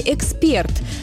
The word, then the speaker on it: эксперт: Так, затруднение порой вызывает ударение в слове «эксперт». Да, именно так, только на последний слог. Именно такой вариант разрешают современные словари. Поставить ударение на первый слог – грубая ошибка.